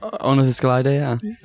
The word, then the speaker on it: Ornithischia: So we're joined, and he is the author of the somewhat controversial Ornithischia. Is that how you say it? – Ornithischia, yeah.